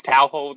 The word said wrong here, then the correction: Toho: That's a big one. I don't know if I'm pronouncing it right. – I think it's Toho. – Toho. Okay.